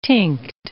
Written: tinct

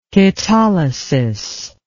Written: ketolysis

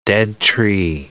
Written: dead-tree

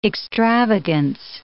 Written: extravagance

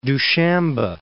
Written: Dushanbe

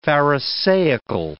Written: pharisaical

